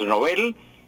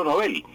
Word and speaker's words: Nobel: Porque el creador de estos premios es un señor de nombre Alfredo Nobel, un sueco, Alfredo Nobel. Y el apellido en sueco es Nobel, no Nobel.